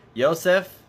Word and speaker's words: Yosef: We're going to try to say Joseph because that's the Hebrew pronunciation of the word, of the name. Yosef, the previous Rebbe's name, our Rebbe's father-in-law, was Rabbi Yosef Yitzchak.